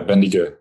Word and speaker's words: Bendigo: I'll see if I say it right. Bendigo? – Yeah, Bendigo. That's the one.